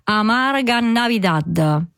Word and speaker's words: Amarga Navidad: E poi tra i titoli più attesi spicca anche, grandi nomi in gara, spicca anche Amarga, non so se lo pronuncio bene, Amarga Navidad, giusto? – Sei perfetta.